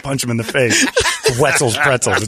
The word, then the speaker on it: Wetzel's Pretzels: Well, no, it rhymes with pretzel. The guy's like Wenzel's Pretzels. That'll be easy to say. – Pretzels. – There's no N in it, you idiot.